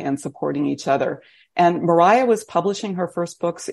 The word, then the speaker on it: Maria: Anna Maria, and she pronounced her name Mariah. We know that because she wrote a poem in which she rhymed it Faya, which I love. So Mariah